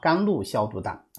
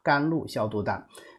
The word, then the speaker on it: 甘露消毒丹: 这其实就是甘露消毒丹啊，甘露消毒丹啊，这个发音有点不准啊。甘露消毒丹，甘露消毒，扣藿香、茵陈滑石、木通畅，对吧？